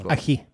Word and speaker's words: aji: Kan Rodda göra sin egen aj pebre? – Yes. – Pebre. – Aj pebre, eller? Ser man. – Aji. – Aji. Aji. Pebre. – Aji. – The J is silent.